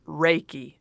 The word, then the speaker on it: Reiki: Reiki, no, you said it I right.